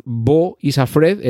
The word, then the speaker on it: Beau Is Afraid: Bueno, Bo Isafred es Beau, ¿vale? Este nombre que se escribe así, Beau afraid Bo is Afred, Este personaje interpretado por Joaquín Fénix.